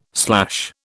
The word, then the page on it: slash: British English